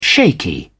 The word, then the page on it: shaky: British English